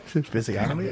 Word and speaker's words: physiognomy: Physiognomy. – But there's a G in there, I don't know how to pronounce it.